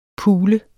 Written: pule

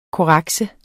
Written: korrekse

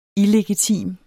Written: illegitim

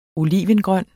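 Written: olivengrøn

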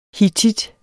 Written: hittit

